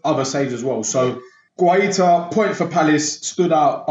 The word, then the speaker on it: Guaita: Uh, sorry, Rich pronounced his name. No, go. Guaita. Guaita. Guaita. – Okay, yes.